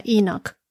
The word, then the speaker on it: Enoch: En inglés se pronuncia Enoch. En algunas traducciones de las Biblias lo deletrean como con H, H-E-N-O-C, en vez de E-N-O-C.